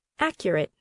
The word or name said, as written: accurate